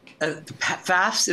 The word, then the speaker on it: Pfaff's: And, you know, that really goes back decades also before Stonewall, because even Walt Whitman, you know, was hanging out at Pfaff's, is pronounced P-F-A-F-F, tavern.